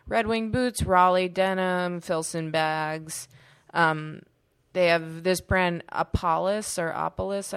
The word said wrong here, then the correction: Apolis: I don't really know how to pronounce it. It's Apolis.